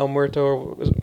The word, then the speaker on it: El Muerto: The comment I read about after hearing about El Muerto, am I saying that right? – Yeah.